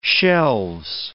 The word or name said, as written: shelves